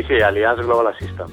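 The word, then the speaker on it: Allianz Global Assistance: Es el actual director de marketing de Allianz Global Assistant. ¿Lo digo bien? – Sí, Allianz Global Assistant. Perfecto.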